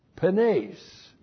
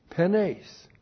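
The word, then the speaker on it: Penes (πένης): Penes. I guess I didn't put it up there. Penes. P-E-N, long E-S. Penes.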